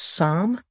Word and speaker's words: Psalm: Historically, context as well, it's actually pronounced Psalm. So the P and the L are silent.